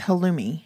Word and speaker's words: halloumi: It's like halloumi cheese or something. But it's a denser, non melting cheese. – It's halloumi.